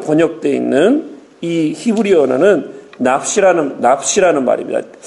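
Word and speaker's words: 납시라는 (נפש): 납시라고 해도 되고요. 납시라고도 납푸라는 그 말에 조금 여운을 넣으시고 발음하시면 됩니다. 제가 히브리가 잘 오래간만에 했더니 잘 안 되는데 납시라는 말로도 얘기해도 되고 납푸시라고 해도 돼요. 하지만 그 푸가 너무 길어지면 안 됩니다.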